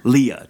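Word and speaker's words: Leia: Leela or Leah? – Leah. Did I say Leela? Sorry. – You said Leela just then. – Sorry, Leah. It's Leah, but it's spelled unusually. It's L-E-I-A.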